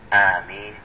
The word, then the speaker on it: آمين: ولكن النطق المشهور والمعروف وغير شاذ وهو المعمول به كذلك آمين بمد الهمزة وتخفيف الميم مع الكسر، وهي بمعنى اسم فعل بمعنى اللهم استجب.